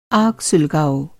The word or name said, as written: सुलगाना